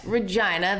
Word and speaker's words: Regina: Yes, Regina, the city that rhymes with fun.